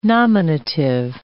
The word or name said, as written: nominative